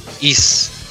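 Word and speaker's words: Ys: Is. Y y S. – Ajá. Así se pronuncian como IS.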